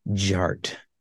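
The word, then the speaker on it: DJ Artt: DJ Art, with two T's for a double dose of that tank tank. The D is silent, so it is just Jart.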